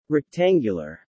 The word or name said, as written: rectangular